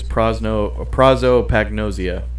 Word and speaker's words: prosopagnosia: It was prosopagnosia.